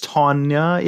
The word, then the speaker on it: Tawna: It's Crash and his sister, Coco, along with their former enemy, Dingo Dial, and his alternate dimension counterpart girlfriend, Tawna? Is that how you say her name? – I think it's Tawna. Yeah, you're close.